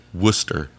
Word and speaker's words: Worcester: No, it's Worcester. – Worcester, sorry. – That's how I was told to pronounce it. Okay. I don't know. – It's going to be a good thing for you to know. – So I didn't look like a dick while I was there. – Exactly. – Because it's spelled like Rochester. – But it's pronounced Worcester.